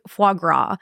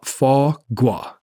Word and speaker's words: foie gras: You said, I was going to say like foie gras. I literally thought that's how you say it. – Foie gras. – Foie gras. – Foie gras. The R is not pronounced.